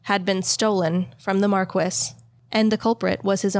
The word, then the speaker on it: Marquis: And yes, that is how you pronounce it. I looked it up. The 5th Marquis of Angsley in the case of the missing jewels. – Are we sure it's not Marquis? – I specifically thought it might be Marquis, but it's spelled different. So I looked up the pronunciation specifically. It is okay. Marquis.